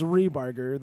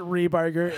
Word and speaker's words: Ripperger: So my last name is Rebarger. That's how we pronounce it. – Rebarger. – But it's spelled R-I-P-P-E-R-G-E-R.